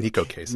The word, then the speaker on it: Neko Case: Here's a tweet from Greenpeace5, Jackie Wilson, who must be thinking of Higher and Higher, and tweet from X Neko, or perhaps Neko, I'm not sure how to pronounce it. – Neko Case. – Neko think, right? – Case, all right.